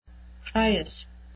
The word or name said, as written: prius